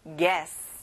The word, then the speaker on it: guess: 여기는 그 u 발음이 안 나요. guess.